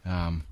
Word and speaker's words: Taron: Taron, that's it.